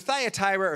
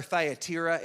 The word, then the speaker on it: Thyatira: It's either Thyatira or Thyatira. It gets pronounced in both ways.